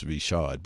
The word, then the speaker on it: Richard: It's spelled Richard, but, you know, he's from New Orleans, so it's Richard.